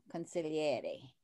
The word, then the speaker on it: consigliere: So wait, I have to ask you, did you end up being somebody's, you know, consigliere? – Right.